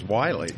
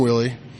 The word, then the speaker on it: Wily: But his birth certificate from Kentucky, it actually said Willie, and it was spelled W-I-L-Y. – Oh. – And that's how they spelled his name on his birth certificate. So which we have pronounced as Wiley. – Wiley. – Yeah, so, I guess you're right. Yeah. But they called him Willie. They called him Willie back then.